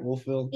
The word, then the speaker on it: Wolfville: Wolfville, yeah.